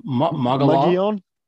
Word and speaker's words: Mogollon: Mogollon rim. – It's named after, I want to say, the first governor of the territory or something. It was a conquistador, I believe. Okay. The Mogollon Rim is the southern edge of the Colorado Plateau in Arizona.